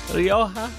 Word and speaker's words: Rioja: R-I-O-J-A is pronounced Rioja. Rioja.